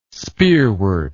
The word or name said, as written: spearwort